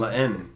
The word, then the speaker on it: La Haine: I saw a movie called La Ein. I probably said that wrong. – La Haine.